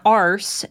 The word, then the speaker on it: arse: You don't pronounce the R. Arse. – Well, that's just ass with a British accent.